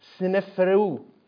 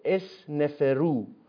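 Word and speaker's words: Sneferu: کهن‌ترین نمونه از این نماد به عصر فرعون سنفرئو، حرف اول سین ساکن، نون و ف کسره داره، تلفظ ساده‌ترش رو بگیم اسنفرو، در قرن 26 پیش از میلاد تعلق دارد.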